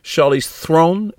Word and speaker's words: Charlize Theron: Charlize Theron in Bombs.